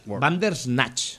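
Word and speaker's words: Bandersnatch: Vanderwatch. – No, Vanderwatch no. Ya lo he dicho mal. Vandersnatch.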